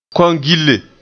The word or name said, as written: kongille